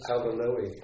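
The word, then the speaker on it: Alva Noë: I kept thinking of the book Out of Our Heads, Alvin Noe, is that how to pronounce it? – Alvin Noe.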